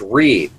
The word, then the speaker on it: Reid: It's pronounced Reed, but it's spelled R-E-I-D.